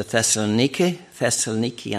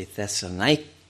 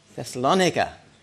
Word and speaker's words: Thessalonica: then they went on to Thessaloniki, and I already am saying it in the Greek pronunciation, but it should be Thessalonica. How do you say it in English? Thessalonica.